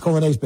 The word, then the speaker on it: Coglianese: See, it's Coglianese, okay? It's pronounced Colonese, but Coglianese.